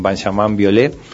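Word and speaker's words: Benjamin Biolay: Como desde hace 3 años estoy yendo mucho a Francia a tocar con Benjamin Violet, se escribe Benjamin Violay, para los que no lo conocen, es un gran músico francés, un artista muy famoso ya.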